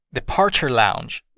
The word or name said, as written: departure lounge